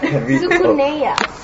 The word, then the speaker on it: Tsukuneya: How do you say it? – Tsukuneya.